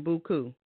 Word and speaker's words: Beaucoup: Now, real briefly, tell me about your nonprofit organization, Beaucoup. Now, do you say it like that? You say it in the French way?